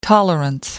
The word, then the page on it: tolerance: American English